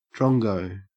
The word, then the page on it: drongo: Australian English